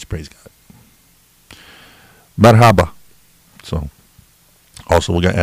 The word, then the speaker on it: merhaba: It's spelled M-E-R-H-A-B-A. So, merhaba. Merhaba. If it's Turkish, it probably sounds like this.